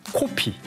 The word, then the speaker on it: 코피: 코피. – 코피. – 예. 코피는 입술을 동그랗게 해서 코피.